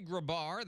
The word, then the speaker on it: Grabar: But Henry Grabar, that is how you say it, like Babar the elephant.